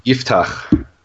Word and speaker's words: Yiftach: Yiftach.